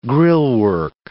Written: grillwork

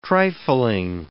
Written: trifling

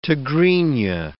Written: Tigrinya

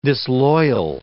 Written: disloyal

